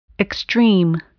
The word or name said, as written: extreme